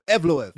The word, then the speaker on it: Evloev: Evloev.